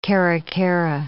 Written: caracara

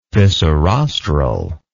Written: fissirostral

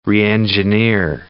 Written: reengineer

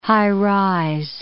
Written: high-rise

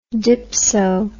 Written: dypso

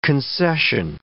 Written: concession

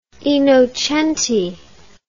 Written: Innocenti

